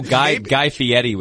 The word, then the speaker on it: Guy Fieri: Oh, Guy Fieri was also at that game.